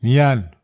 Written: nian

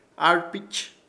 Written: ARPICH

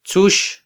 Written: tzux

